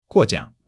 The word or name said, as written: guo4 jiang3